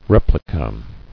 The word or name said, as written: Replica